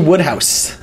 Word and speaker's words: Wodehouse: P.G. Woodhouse. Spelled Wodehouse, but pronounced Woodhouse. – Wodehouse? – Yeah, W-O-D-E. – W-O-D-E House.